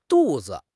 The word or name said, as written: du4 zi5